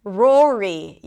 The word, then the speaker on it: Rory: The next name is Rory. Rory. Yes, more R's. We start with an R sound, then it's the OR as in four.